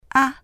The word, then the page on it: 啊: a